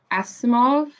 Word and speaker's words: Asimov: I just read Foundation by Isaac Asimov.